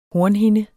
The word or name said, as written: hornhinde